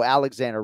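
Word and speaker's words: Aleksandar: It's like the bank, Santander. – Alexander. – So it's spelled like Alexander, like S-A-N-D-A-R. And when he slows it down, he says it that way. But when he speeds it up, it's more like Alexander.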